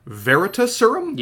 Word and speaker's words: Veritaserum: I like that everyone is on the same page with Umbridge and the Veritaserum? – Yeah.